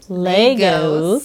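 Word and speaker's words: Lagos: She was invited to speak at the third annual GT Bank Autism Seminar in Legos. – Thank you. Yep. I had to put a little long A. – A long A. – Yeah. Mm-hmm. Legos, Nigeria, where she gave a talk to parents and professionals on the topic of incorporating speech play in the treatment of ASD.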